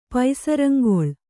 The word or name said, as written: ಪಸರಂಗೊಳ್